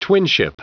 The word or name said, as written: twinship